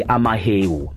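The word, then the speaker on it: amahewu: But the proper pronunciation of the South African delicacy is actually amaheu.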